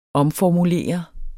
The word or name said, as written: omformulere